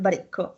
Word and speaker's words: Baricco: Alessandro Baricco.